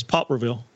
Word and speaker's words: Poplarville: It's good to see Mississippi natives do that, and I hope everyone uh, really catches on to the uh, correct, correct pronunciation of. "pop." you put too much emphasis and too much grammatical uh, accuracy in it, but I think the real pronunciation is Popreville.